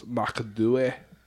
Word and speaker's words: Macduff: The correct pronunciation of Macduff historically is McDooey.